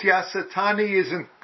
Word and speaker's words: Yasutani: Whereas whoever's translating this and Yasutani, am I saying his name right? – Yeah, it's Yasutani didn't do the translating.